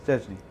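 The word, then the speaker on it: Szczęsny: Chieschke? I don't know how to say it, but Chesney. – There we go. I had it right.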